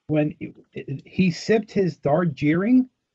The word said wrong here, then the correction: Darjeeling: Am I saying that right? And then put his mug back into a coaster. Am I saying that yes. right? – Darjeeling.